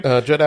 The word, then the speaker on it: Judd: So like I said, she's a director, Jude Apatow. Is it Apatow? Is that how you say his name? – Judd Apatow.